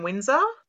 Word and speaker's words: Winzar: So my personal Facebook is just Lauren Windsor. My surname is spelt Winzar, just to be difficult.